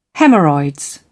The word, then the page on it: hemorrhoids: British English